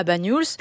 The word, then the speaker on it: Bagnols: Comment on le prononce? – Bagnols. Oui, ouais. C'est ça.